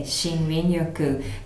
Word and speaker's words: Shinrin-yoku: Shinrin-yoku. – Yeah, Shinrin-yoku.